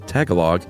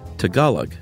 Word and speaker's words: Tagalog: Chuck said Tagalog. It's actually Tagalog.